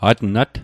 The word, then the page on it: atnat: Mi'gmaq